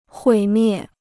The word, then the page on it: 毁灭: huǐ miè